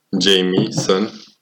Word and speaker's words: Jameson: de, de Jameson Jamieson. – Da, să știi că, că așa e pronunția corectă, Jamieson. Nici eu am știut asta,